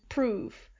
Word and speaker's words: prove: Like, I read one, I think it was Shakespeare, love and prove. – Right. – So for us, that doesn't rhyme even a little bit. But it said that prove would have been pronounced more like love.